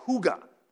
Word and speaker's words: hygge: Now, of course, you Scandinavians have tried to romanticize this idea with what the Danes call hygge. That is how you pronounce that word.